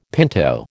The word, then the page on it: pinto: American English